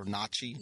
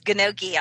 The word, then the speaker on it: gnocchi: They had like gnocchi. Is that how you say it? Or nachi? – Gnocchi. Yeah, you got to put a little roll in front of the N. I'm bad at it.